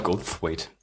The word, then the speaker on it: Goldthwait: Ich habe ja in unsere, unseren Themenspeicher auch mal Bobcat Goldthwait Bobcat Goldthwaite. Dankeschön.